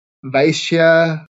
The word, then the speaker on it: Vaishya: I, can you tell me how to pronounce those words? Yeah. So Brahmins are the priests. Kshatriyas, yeah, are the warriors. Vaishya are sort of tradesmen and farmers, and Sudras are servants.